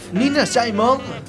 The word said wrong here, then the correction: Nina Simone: No, Simone. – ¿Nina Simon? – No, Simone. – Nina Simone.